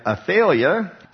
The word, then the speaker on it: Athaliah: And then Athaliah, actually is how you're supposed to pronounce it.